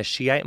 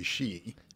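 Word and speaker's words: Shia: Well, it's probably better to say she. – She. – Actually, when you say she, there's the sound in there that you probably can't do,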